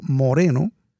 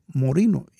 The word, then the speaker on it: Moreno: Era de apellido Moreno. Ay, y entonces Dios. Nosotros le decíamos Morino, porque en inglés se pronuncia Morino, y él fue al ejército.